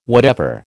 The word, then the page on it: whatever: American English